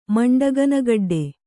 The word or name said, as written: ಮಂಡಗನಗಡ್ಡೆ